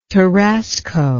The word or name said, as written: Tarasco